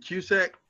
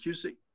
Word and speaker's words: Cusack: I pronounce it Cusack. I heard people pronounce it Cusick, but I pronounce it Cusack.